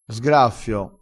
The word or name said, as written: sgraffio